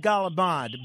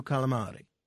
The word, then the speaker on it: calamari: Well, I always said galabad, but then when John Vito called in, he had such a beautiful way of saying calamari that I have changed my pronunciation to calamari.